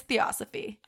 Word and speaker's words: Theosophy: That's how you say it. Not Theosophy. It's Theosophy.